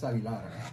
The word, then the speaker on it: Aguilar: Yeah, it's Aguilar. You want me to spell that for you? A-G-U-I-L-A-R.